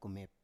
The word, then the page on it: kumep: Biak